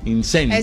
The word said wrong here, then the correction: incienso: Es incienso.